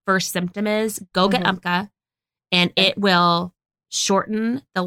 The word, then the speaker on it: Umcka: And it's one that I use called Umca. – It's spelled, yes, you swear by this. – I do. It's spelled U-M-C-K-A.